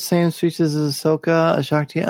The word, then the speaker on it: Shaak Ti: After having watched the Gennady Tartakovsky, hand-drawn version, there's a character called, see if I'm pronouncing it right, Shaak Ti, that's right.